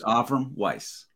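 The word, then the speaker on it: Avrum Weiss: And Doc, I always butcher your last name, and I apologize. – It's all right. But it's Avram Weiss.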